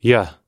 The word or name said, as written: yeh